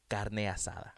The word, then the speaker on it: carne asada: But you can't say it like that. – Do it. – Carne asada. – Asada. – Come on. Be better.